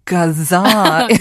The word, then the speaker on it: 가즈아: it's three 가주아, but you pronounce it as kaza.